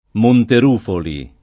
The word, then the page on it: Monterufoli: lingua italiana